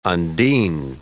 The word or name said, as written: undine